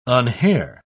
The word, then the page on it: unhair: British English